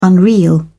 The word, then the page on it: unreal: British English